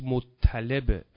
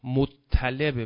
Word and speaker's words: مطلب: جناب عبدالمطلب که درستش مطلبه بر وزن مطلع، بعضی ها اشتباهاً تلفظ میکنن مطلب، مطلب غلطه، مطلبه مثل مطلع،